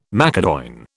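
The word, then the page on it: macédoine: British English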